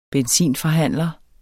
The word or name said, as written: benzinforhandler